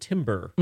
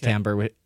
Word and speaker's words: timbre: It adds sort of a different timbre to it. – Am I using the word timbre? – Timbre. Yeah, it's timbre. Even though it's spelled T-I-M-B-R-E.